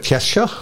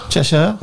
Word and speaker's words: Cheshire: Også i, du hadde så fin uttale på det, Cheshire. – Cheshire, ja.